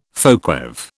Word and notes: folkweave: British English

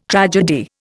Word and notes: tragedy: Indian

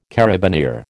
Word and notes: carabineer: American English

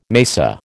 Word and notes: mesa: American English

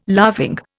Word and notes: laughing: Indian

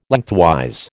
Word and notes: Lengthwise: American English

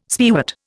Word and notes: spearwort: Indian

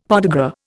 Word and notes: podagra: Indian

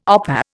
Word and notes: opah: Indian

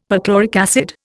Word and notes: perchloric acid: Indian

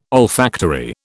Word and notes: olfactory: British English

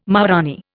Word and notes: maharanee: Indian

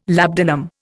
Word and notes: labdanum: Indian

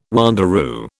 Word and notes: wanderoo: British English

